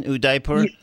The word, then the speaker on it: Udaipur: You guys say, okay, well, related to that, we're just going to settle into Udaipur. Am I doing pretty decent on the pronunciation, Udaipur? – You're perfect.